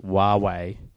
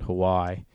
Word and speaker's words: Huawei: But the other news was I've been told it's pronounced Huawei. Some people might call it Hawaii.